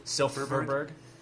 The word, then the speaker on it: Silfverberg: Was it Cogliano and Silfverberg yeah. with Kesler? That's Silf- been their best line. Silf- Silfverberg. The F is silent.